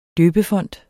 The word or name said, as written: døbefont